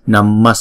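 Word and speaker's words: namaḥ: Thì cái chữ Visarga này chúng ta có thể bỏ nó đi và chúng ta không đọc là Namma, mà chúng ta đọc là Namma Sammanta, Namma Sammanta.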